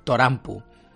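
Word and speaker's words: Torampu (トランプ): Eh, o, bueno, ellos lo pronuncian Torampu.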